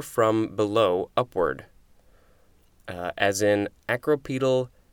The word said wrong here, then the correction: acropetal: I think it's acropetal, and petal is like a petal of a flower, which is why you've got that example of floral buds.